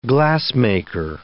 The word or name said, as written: glassmaker